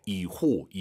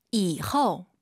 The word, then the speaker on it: 以后: 뭐뭐한 후에 이호 이호 아니에요. 오유 발음은 어우예요. – 以后.